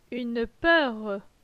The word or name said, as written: Une peur